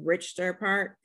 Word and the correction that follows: Richton Park: Am I saying that right? – Mm-hmm. Richton Park.